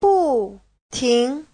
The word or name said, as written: bu4ting2